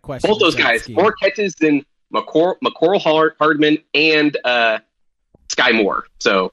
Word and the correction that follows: Mecole: You keep saying Hardman's name wrong. And it's the way you properly say it is fun. So you should say it right. – Mercole? – Mecole. – Mecole. – Is there an R there, isn't there? – No, it's not. It's Mecole Hardman.